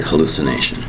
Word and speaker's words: hallucination: It's five syllables, the word hallucination. Spelled H-A-L-L-U-C-I-N-A-T-I-O-N. Hallucination. Notice, five distinct syllables, which are hal-lu-si-na-tion. Hallucination. Again, the spelling is H-A-L-L-U-C-I-N-A-T-I-O-N.